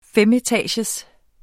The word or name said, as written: femetagers